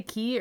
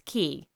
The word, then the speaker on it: Cay: Yes, it's spelled C A Y, but they pronounce it Key.